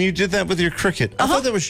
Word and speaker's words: Cricut: C-R-I-C-U-K. – It's Cricut. – C-R-I-C-U-K. – C-U-T.